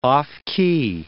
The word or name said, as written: off-key